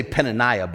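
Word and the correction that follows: Peninnah: but her name actually is pronounced, I think, Penina, actually, right? Like Panini. I think it's actually pronounced Penina.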